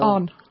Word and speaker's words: An Post: A-N-P-O-S-T. – Well, we pronounce it on. – On, sorry. I'll learn. I've got a long way to go. Yeah, the On Post Project.